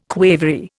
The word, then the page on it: quavery: Indian